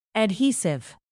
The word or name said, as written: adhesive